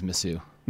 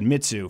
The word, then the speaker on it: Mizzou: I think it's Mizzou. – Mizzou. I said Mitsu for a long time, because there was a French-Canadian singer. – That's Mizzou. – Incredibly wrong. – It is absolutely wrong.